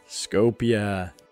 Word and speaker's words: Skopje: Skopje. – That's how they would pronounce it over there. So I've heard it pronounced in different ways, however we want to Americanize it. But I've heard them say Skopje.